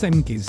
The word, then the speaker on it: Cengiz: I think it's pronounced Cengiz, C-E-N-G-I-Z, or Z.